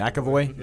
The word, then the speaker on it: McAvoy: McAvoy? – Yep.